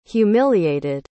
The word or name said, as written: humiliated